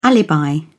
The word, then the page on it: alibi: British English